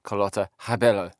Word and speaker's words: Carlotta Rebello: Or as I discovered when we got there, she's not called Carlotta Rebello. She's called Carlotta Rebello. Yeah, there was a whole kind of like different pronunciation going on.